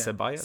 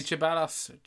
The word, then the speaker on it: Ceballos: It starts with C-H, but, or no, C-E. – C-E-B. – But it's pronounced like T-S, yeah. Ceballos. – See, Ceballos. I'm calling him Ceballos now.